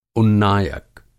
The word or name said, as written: उन्नायक